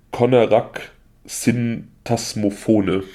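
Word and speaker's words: Konarak Sinthasomphone: Ich hoffe, ich spreche den Namen falsch, richtig aus. Ich hoffe, wie gesagt, bitte, bitte steinig mich nicht. Konarak Syntasmophone. Also, Ich glaube, das hast du richtig ausgesprochen.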